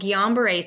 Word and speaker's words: Guillain-Barré: It's called Guillain-Barré syndrome.